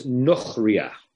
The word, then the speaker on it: nochriya: And it's a kamatz katan, so it's nochriya. Now, even though it looks like it's an A, it's pronounced nochriya.